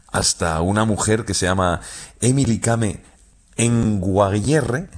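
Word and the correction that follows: Kngwarreye: lo he dicho mal. – Emily Kame. – Con Guarreye. – Emily Kame con Guarreye, sí.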